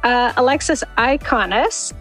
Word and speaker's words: Alexis Iaconis: Now, since I butchered your last name, do you mind saying it for everyone so they know? – Yeah, my name is Alexis Akanis, and it is a really unique name.